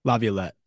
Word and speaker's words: Laviolette: Because for me, the three names that I've seen out there, like Laviolette, I've butchered that name, but Laviolette.